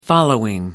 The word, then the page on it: following: American English